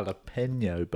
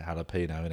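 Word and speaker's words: jalapeño: I think we sort of say jalapeno, innit? But I've heard some people go jalapeno. It's like, it begins with J, doesn't it? Yeah. – Yeah, but it's a silent J.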